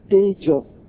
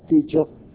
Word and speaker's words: tejo: So in Pali, E, you pronounce A. A, so tejo. O is O. We usually pronounce O, tejo.